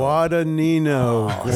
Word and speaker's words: Guadagnino: Guadagnino. Say it right for me. – It's Nino. Guadagnino. – Such an idiot. – Guadagnino.